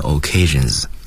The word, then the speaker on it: occasions: 第三声单数 does，强调的介词 on 结合着后面的 occasions，记住为结尾的发音是 z，因为它的这个倒数第二个音节是浊辅音，嗯，所以呢 occasions。